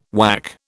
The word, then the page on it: WAC: British English